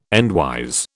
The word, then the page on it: endwise: British English